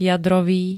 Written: jadrový

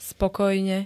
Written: spokojne

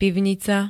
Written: pivnica

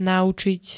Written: naučiť

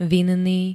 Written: vinný

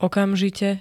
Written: okamžite

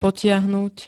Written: potiahnuť

